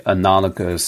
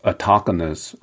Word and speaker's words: analogous: And interesting enough, I would use the analogous situation, autogonous.